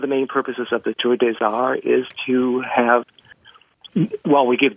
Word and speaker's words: Tour des Arts: It's T-O-U-R-D-E-S-A-R-T-S, but we pronounce it Tour des Arts.